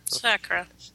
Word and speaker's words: Sakura: You do not accent the second syllable. Ever. – Sakura. – If you need an American accent, put it on the first syllable.